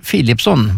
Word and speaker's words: Philipsson: Och står det i den ordningen i ett namn, då uttalas det som ett F. Så Lena Philipsson var det vi hade hört där.